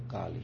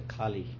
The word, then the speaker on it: Kali: Well, let's just say Kali. In America, they say Kali. They cannot say Kali. They say Kali. Kali Ma, like that.